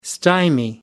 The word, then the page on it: stymie: American English